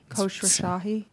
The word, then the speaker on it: Khosrowshahi: interviewing Uber CEO Dara Khosrowshahi. Joanna, That's right. – He told you that's the correct pronunciation, right? – Khosrowshahi. I don't know. I got it right in my video.